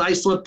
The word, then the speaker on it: Islip: There, there's a town on Long Island called Islip, and it's it's pronounced Islip, but it's spelled I S L I P.